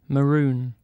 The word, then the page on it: maroon: British English